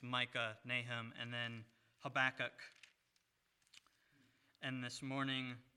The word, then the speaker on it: Habakkuk: With that said, if you would, please turn with me to Habakkuk, or as the English pronounce it, Habakkuk.